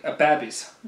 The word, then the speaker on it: babbies: Three young babbies, Reynolds pronounced this last as they did in the Northern Baronies. Babbies.